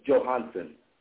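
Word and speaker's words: Johonson: It's J-O-H-O-N-S-O-N, and it's Johansson.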